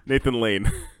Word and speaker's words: Nathan Lane: Did you call him Nathan Lang? Did you say Nathan Lang? – What's his name? – Nathan Lane. – Lane, okay.